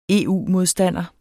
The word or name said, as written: EU-modstander